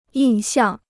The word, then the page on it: 印象: yìn xiàng